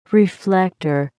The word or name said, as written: reflector